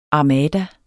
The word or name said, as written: Armadaen